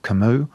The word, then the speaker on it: Camus: There's a lot of talk about Albert Camus, which, as I've just discovered, doesn't rhyme with bus.